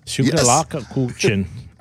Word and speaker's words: Schokoladenkuchen: Schokoladenkuchen. Oh yes. – Schokoladenkuchen. No, it's Schokoladenkuchen. – There's an O in it? – Yeah. Yes. Two O's.